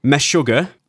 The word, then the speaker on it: Meshuggah: I need to make sure I say this right. The Swedish metal band, Meshuggah. – Yeah, that sounds right. – Yeah.